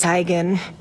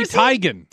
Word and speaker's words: Teigen: You've been saying it wrong. – What? – I'm tired of living sly. It's Teigen. Isn't it, Mom? – Yep. – It's her mom. – Told you. – Chrissy Teigen.